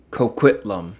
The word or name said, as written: Coquitlam